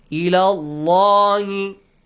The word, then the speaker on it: اللَّهِ: With the letter lam in the word Allah. – It is, Allah is pronounced heavily. – Heavily, yes. إِلَى اللَّهِ.